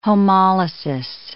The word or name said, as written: homolysis